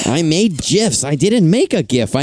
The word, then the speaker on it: GIF: Graphic starts with a G with a hard G. So I think you're just being difficult on purpose, creator of GIFs.